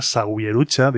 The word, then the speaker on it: Zawierucha: El nombre del grupo es Zawierucha. Se escribe Zawierucha, con W y con Z, Zawierucha, diríais los seseantes del sur de la península, Canarias, América Latina, etcétera, etcétera.